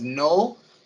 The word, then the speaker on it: Knull: The King in Black is an entity known as Null, spelled K-N-U-L-L. The K is silent.